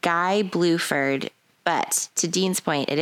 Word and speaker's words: Guy Bluford: It was either it's Guy Bluford. Or Guy Bluford. – Nope, it's Guy. – Junior. – It's Guy Bluford.